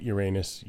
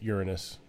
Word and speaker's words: Uranus: Uranus, Uranus, your choice.